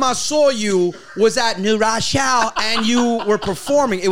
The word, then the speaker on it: New Rochelle: The last time I saw you, I think it was in New Rochelle. – It was White Plains. Why do you say it like that? It's New Rochelle.